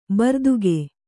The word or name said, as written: ಭೃತಕ